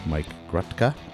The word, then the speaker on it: Mike Grutka: I'm sitting here with my guest, Mike Grutka, and I pronounced that correct? – You did.